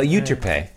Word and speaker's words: Euterpe: Yeah, Euterpe. – Euterpe.